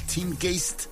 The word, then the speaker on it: Teamgeist: En el 2006 estaba el Teamgeist, más bien, esa es la pronunciación correcta.